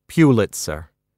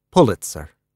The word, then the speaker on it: Pulitzer: "It's not Pulitzer. It's Pulitzer."